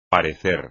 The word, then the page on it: parecer: espagnol